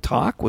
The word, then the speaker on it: Taake: Yeah, that last one with Talk. Was it Talk? Is that how you pronounce it? – T-A-A-K-E. – T-A-A-K-E.